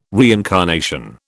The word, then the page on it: reincarnation: British English